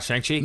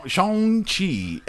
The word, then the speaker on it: Shang-Chi: No, so it's not Shang-Chi. – No, okay. It's not that American A. – Shang-Chi and the Legend of the Ten Rings.